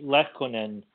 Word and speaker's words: Lehtonen: Arturi Lehtonen is the way to pronounce his name.